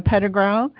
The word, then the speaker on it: Pedregal: Yes, so there's actually a marina just south of David in the little town of Pedregal, and there's some big boats and little boats there.